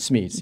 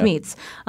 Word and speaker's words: Smeets: So we hear David McGee telling you again about this Dutch couple, Jan and Josh Meets, is that how you say it? – Smeets, yeah. – Smeets.